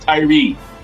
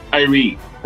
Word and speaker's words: Tyree: No, it's pronounced Tyree. Tyree.